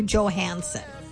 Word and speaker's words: Johansson: How do you say, is it Johansson or Johansson? – Johansson. – Is that the correct way? – It's my correct way. – All right. – I mean, that's what it looks like. It's J-O-H-A-N-S-S-O-N, Johansson.